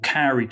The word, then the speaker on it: kauri: In particular, there are these trees called kauri trees that are in New Zealand.